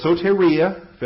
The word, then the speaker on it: soteria: Vocabulary is soteria, S-O-T-E-R-I-A. It's the long O and long E. The omega and the eta. S-O-E-T-E-R-I-A, soteria.